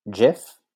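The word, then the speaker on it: GIF: It is pronounced Jif, Zendaya.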